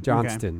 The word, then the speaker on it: Johnston: Heary, we have an act off between Brendan Walsh and Davey Johnson. – Johnston. – Okay. Johnston. That T is invisible. – It's not in my name, but it's pronounced. – Yeah. – It's pronounced, but not written.